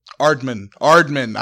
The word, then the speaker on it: Aardman: I don't really like the art style of Ardum, but I'm sorry. It's just not my thing. – Aardman. I've been saying it wrong. It's Aardman.